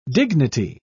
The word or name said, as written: dignity